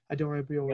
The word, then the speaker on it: Adarabioyo: Adarabioyo.